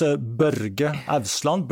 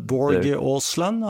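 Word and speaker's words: Børge Ousland: I'm going to mispronounce his name, Borg Osland. And can you, how's his name pronounced? – I think you said it pretty good. – Pretty good, okay. – Right. In Norwegian, it's Børge Ausland, Borg Ausland.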